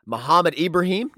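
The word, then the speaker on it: Mohamed Ibrahim: I'm going to butcher this name, Muhammad Ibrahim. No, I didn't. It's crushed that. – It. Nailed it.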